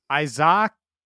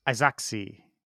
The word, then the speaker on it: Izaakse: Oh, I-Z-A-A-K-S-E. – I would say you're probably pretty close. – Isaacse. But do you hold the double A longer? Isaacse. – No, because like, isn't Isaac spelled with two A's as well? – Oh, yeah.